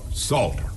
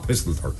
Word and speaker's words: Psalter: P-S-A-L-T-E-R, Salter. Not Pistleter. The P is silent.